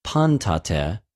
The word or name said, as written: παντοτε